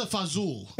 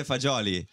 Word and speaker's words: fagioli: What about pasta fazool? – Pasta fagioli.